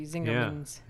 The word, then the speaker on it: Zingerman's: Well, soft G or hard G? – I'm saying that with a hard G, Zingerman's.